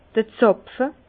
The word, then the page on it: d'r Zàpfe: Bas-Rhin; Herrlisheim